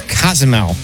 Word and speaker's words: Cozumel: Kazumel? – Yep. Actually, oh my God. – The correct pronunciation is Cozumel.